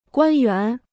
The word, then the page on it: 官员: guān yuán